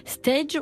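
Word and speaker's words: Steige: Monsieur Meister, qui est donc boulanger à Stage, on dit, c'est comme ça qu'on prononce, on est d'accord, on est dans une commune du Bas-Rhin.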